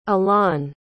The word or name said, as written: alon